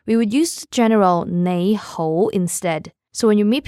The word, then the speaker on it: 你好: 你好. Let's say it slowly now. 你好. 你好. Now both of the syllables are using rising tones. 你好. The second one is slightly higher than the first one.